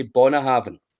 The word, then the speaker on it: Bunnahabhain: they say it the same way as I do. They say Bunnahabhain. – Bunnahabhain. – Yes. – Oh. – So if the real way is the way that the locals say it, then that would be how you say it.